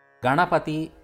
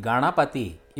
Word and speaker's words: Ganapati: Ganapati means Ganesha. The second syllable in the word Ganesha or Ganapati is a cerebral sound. It should be pronounced as na rather than na.